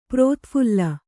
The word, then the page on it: ಪ್ರತಿಫಲ: prati phala